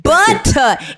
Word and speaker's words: Buttah: And it's spelled B-U-T-T-A-H. – Yeah. – Butter. And it's got two and it's got two T's in it because it lets you know you need to put some shea on your tail, on your butt.